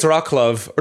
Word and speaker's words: Wrocław: I pronounced Wrocław wrong. I don't know how to pronounce it. But to spy on warehouse Wrocław, apparently. – Okay, Wrocław. Someone sent me an email saying you pronounced it wrong when you went on the radio. – Wrocław. I think it's Wrocław. Or no, because it's the L is, if it's got a squiggly, it's a W.